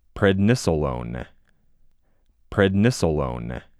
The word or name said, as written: PrednisoLONE